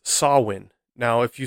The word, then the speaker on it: Samhain: But it is pronounced Samhain. Sometimes you can also see it spelt more phonetically the way it's pronounced as S-A-U-I-N.